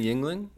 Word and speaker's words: Yuengling: then drink Yuling. – Right. Um, you mean Yuengling? Yuengling.